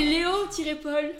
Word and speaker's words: Léo-Paul: C'est Léo-Paul. – Paul comme le prénom. – Et pas Léopold.